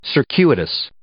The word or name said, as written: circuitous